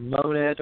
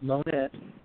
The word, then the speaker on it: Monette: It's the little town of Monette, Arkansas. Monette. And not pronounced Monet, but pronounced Monette. Which I guess if you were French, you might pronounce it Monet.